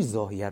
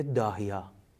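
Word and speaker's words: ضاحیه: ببین کاری که تو زاهی انجام داد تو جنگ 33 روزه، حرب تموز خود اونا میگن، جوری زاهیه رو زد، جنوب بیروت رو میگن زاهیه، جوری زد که معروف شد به استراتژی داهیا که همون زاهیه است، اونا زاد و ده تلفظ میکنن.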